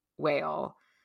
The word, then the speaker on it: Mhaol: And that's where Grainne Whale comes from, is the idea of having, I think it's Grainne Whale. It's spelled M-H-A-O-L. And I believe M-H followed by an A is a W.